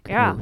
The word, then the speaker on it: Goath: Well, they're called, I'm probably fucking this up, Goath? – G-O-A-T-H? – Hey, yeah, I must be saying it correctly. Goth.